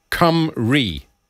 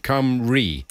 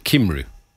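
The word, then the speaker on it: Cymru: It's pronounced cum-ri. Plaid cum-ri. It is not, as Chris says, a Korean party, plaid kimru. So cum-ri is how it's pronounced.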